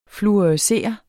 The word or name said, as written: fluorescere